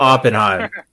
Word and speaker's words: Oppenheim: Oppenheim.